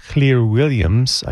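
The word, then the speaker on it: Llŷr Williams: A piece now by Beethoven, performed by Welsh pianist Clear Williams. I know how to pronounce that because my wife is Welsh.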